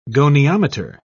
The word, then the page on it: goniometer: British English